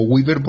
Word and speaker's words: Gwibber: Yo lo pronuncio como Weaver porque me suena así, por la W.